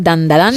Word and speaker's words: Dandadan: Tenemos Dandadan, creo que lo he dicho bien, Dandadan, Sí, Dan, Dan.